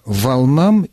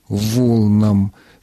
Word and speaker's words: волнам: Действительно, волны, волн, волнам и волнам. То есть в формах косвенных падежей этого существительного вы можете ставить, смело ставить ударение так, как вам кажется более удобным.